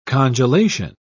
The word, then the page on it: congelation: British English